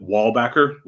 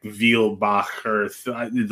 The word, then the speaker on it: Weilbacher: So every time I interact with a German human, I will always have to deal with this interaction because our family pronounces it Wallbacher, like a wall and a back that does. But that's not how it is. Actually pronounced it's like Weilbacher, like this, like that excess Germany-ness that you need to have in there.